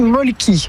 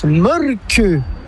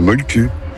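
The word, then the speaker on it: Mölkky: Ça s'écrit Molki, mais ça se prononce Molke. – Le Molke. – Plutôt un E qu'un E, mais pas un U. Comme ça fait Molke.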